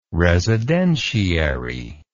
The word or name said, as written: residentiary